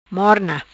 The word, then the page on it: Morna: Patuá